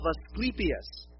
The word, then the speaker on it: Asclepius: But it's the temple of Asclepius. Asclepius. Now, Asclepius, you're like, oh yeah, Asclepius. Of course, everybody knows about Asclepius.